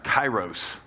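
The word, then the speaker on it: kairos (καιρός): The word opportunity there is that's the ancient Greek word pronounced kairos.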